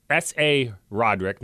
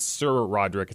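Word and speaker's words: SaRodorick: Sir Roderick Thompson, that's S.A. Roderick, not Sir Roderick.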